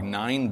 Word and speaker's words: Nain: Where is the city of Nain? It's actually pronounced more like Nine, but I say Nain just to keep it simple.